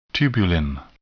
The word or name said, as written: tubulin